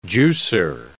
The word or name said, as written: juicer